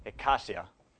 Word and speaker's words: eikasia: The first is called ekasia, which is spelled E-I-K-A-S-I-A.